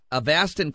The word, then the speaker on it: Avastin: Avastin costs about $50 per injection.